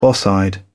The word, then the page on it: boss eyed: British English